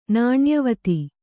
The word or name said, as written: ನಾಣ್ಯವತಿ